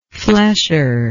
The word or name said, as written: flesher